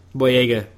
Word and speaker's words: Boyega: Um, John Boya. Boyega. Boyega, I say his name wrong over and over.